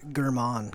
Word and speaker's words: Gherman: It's Gherman.